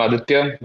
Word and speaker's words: Aditya: Hey, everyone, I'm Aditya.